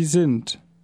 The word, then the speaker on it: sind: Sie sind. You notice the same thing here. The D of sind is pronounced like a T because it is at the end of the word.